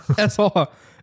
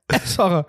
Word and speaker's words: Essacher: Äh, ich habe das Gefühl, ich, ver, ich, ver, ich verliere irgendwie immer eine Silbe. Esser. Essacher.